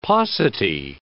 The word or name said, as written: paucity